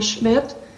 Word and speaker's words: Schmidt: Mr. Chairman, members of the committee, my name is Donna Schmidt, that's spelled S-C-H-M-I-D-T, and I would also like to thank the subcommittee and A&E.